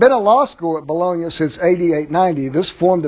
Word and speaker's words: Bologna: Yeah, that's it, Bologna. Okay. I hate mispronouncing things. The first universities were those of Bologna, which is in northern Italy, and Paris, which is in northern France.